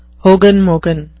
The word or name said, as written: hogen-mogen